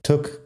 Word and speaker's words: took: Antes de seguir, vamos a escuchar un poco la pronunciación porque took y put la vocal es igual, aunque se escriba tuk con doble o y put con una u. La vocal suena igual. Tuk, put. Es como una u corta, u.